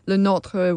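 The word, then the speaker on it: Le Nôtre: Le Notre was a real guy and a really kick-ass gardener, you know.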